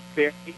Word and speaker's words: Barre: And it's Barry, and it's you had the good comparison of a more famous or well-known town that people have heard of, Wilkes-Barre. So it's spelled the same way.